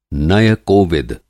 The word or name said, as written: नय-कोविद